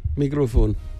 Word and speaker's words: Mikrofon: Mikrofon.